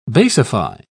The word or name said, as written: basify